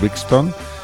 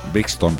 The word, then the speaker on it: Brixton: El temazo era Guns of Brixton. Brixton, perdón.